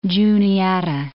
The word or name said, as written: Juniata